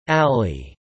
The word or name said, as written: alley